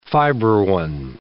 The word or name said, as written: fibroin